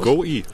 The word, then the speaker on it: go-e: Und ja, die gute Joey. – Was? – Von die Wallbox. – Ja. – Also, Go Joey. Ach so, spricht man das aus? – Go-E. – Go-E, ja, ich dachte Joey.